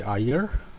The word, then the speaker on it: Iyer: I'm Dr. Ravi Iyer. Name is spelt a little bit off. It's I-Y-E-R.